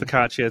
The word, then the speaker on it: focaccia: It's focaccia. It's spelled F-O-C-C-O-C-I-A.